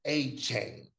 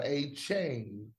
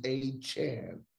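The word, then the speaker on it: Achane: It's Devon A-Chan, C-H-A-N, not A-Chain. He ain't 2 Chainz, he's A-Chan. Devon A-Chan.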